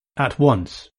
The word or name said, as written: at once